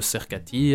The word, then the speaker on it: Sercati: Il y a la page bêtement de Cercati, hein, ça s'écrit comme ça s'entend. – S-E-R-C-A-T-I. – La page Facebook, c'est Cercati ?